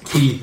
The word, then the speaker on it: Quay: Key is pronounced. – Connors Quay. I always say Quay.